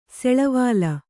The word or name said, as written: ಸೆಳವಾಲ